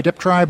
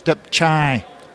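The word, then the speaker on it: Dương Phục: most people say Deptry, but in fact it's Depchai, chai like a ch, but it's a tr.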